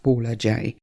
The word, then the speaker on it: Paula J: My name is Paula Jacobs, which is what the J is short for, but I prefer the shortened artistic version of Paula J. And of course, I love the fact that it rhymes with let's find out how the cookie crumbles today, because, you know, crumbling cookies is something I am damn good at.